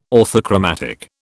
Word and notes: orthochromatic: British English

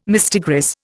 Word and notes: mistigris: Indian

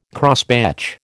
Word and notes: Crosspatch: American English

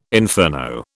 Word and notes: inferno: British English

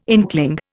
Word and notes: inkling: Indian

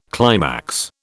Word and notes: climax: British English

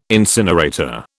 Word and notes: incinerator: British English

